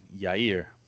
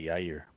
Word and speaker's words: Jair: There's more than one guy named Jair, or pronounced Yair, but the most important one is the guy named Jair. I'm just going to say Yair,